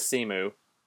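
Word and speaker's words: Cemu: And I'm not sure how to say this. Simu? – I've said it as Simu.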